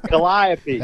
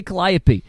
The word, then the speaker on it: Calliope: So this is what's frustrating about this is I hear all of you say this, but if you go to New Orleans, none of them say Calliope. They say Calliope. – What do they know? – So I fight everybody at camp about this every year. Calliope is not how they say it down there, and I think that it's born from the Creole language, is it not?